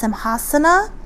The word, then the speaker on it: Simhasana: And in Sanskrit, it's pronounced Simhasana.